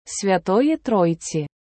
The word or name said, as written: Святої Тройці